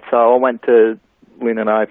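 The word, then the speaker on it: Lindenow: Am I saying it right? Lindenau or Lindenau? – Lindenau.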